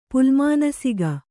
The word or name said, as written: ಪುಲ್ಮಾನಸಿಗ